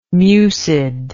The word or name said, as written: mucid